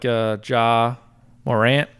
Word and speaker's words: Ja Morant: I like Ja Morant, I think.